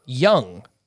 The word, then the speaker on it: Jung: Josh Young, not Jung.